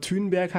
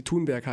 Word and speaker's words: Thunberg: Er hat es ändern lassen, aber Thünberg halt nicht und Honestly auch nicht. – Es gibt so. nur dumme Menschen, die ihren Namen nicht aussprechen können. Ich ja auch nicht bis vor kurzem. Ich dachte ja auch, dass sie Thunberg heißt. Weil alle Thunberg sagen und Nestle.